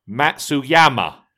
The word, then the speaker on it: Matsuyama: There is a Y in his name. It is not Matsuyama, as in Satsuma, I think, is what he's basically saying. Cheers, Glenn." So from now on, Matsuyama is how I'm going to pronounce it.